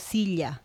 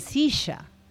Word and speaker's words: silla: Por exemplo, eh, bom, não pronunciaria igual que um argentino eh, em Peru, decimos silha donde estamos aquí sentados. los argentinos dirían silla, ¿no? – Ah, entendí.